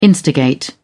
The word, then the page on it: instigate: British English